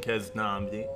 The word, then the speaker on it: Keznamdi: The song is called Blue Skies featuring Keznamdi.